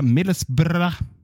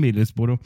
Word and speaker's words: Middlesbrough: Hur som helst, Michael Carrick närmar sig alltså gamla fina anrika Millesbra eh, som man kan uttala det om man vill, eller så säger man Middlesbrough. Men eh,